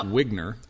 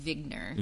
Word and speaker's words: Wigner: So this is a quote from, is it Wigner or Wagner? Wigner. – I've always pronounced it Wigner.